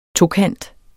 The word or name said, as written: tokant